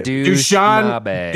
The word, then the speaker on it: Dushanbe: Dushanbe.